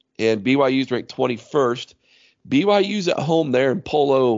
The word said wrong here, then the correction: Provo: or I think it's Polo or however you pronounce that, Utah. – Provo? – Provo, thank you.